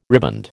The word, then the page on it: riband: American English